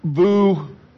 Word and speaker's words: Vujicic: His name is Nick Vujicic. That's how it's pronounced. But if you look him up, it's V-U-C-I.